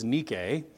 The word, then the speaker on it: Nike: Okay, Nike is a Greek word that the company pronounces wrong. The word is Nike, and it means victory.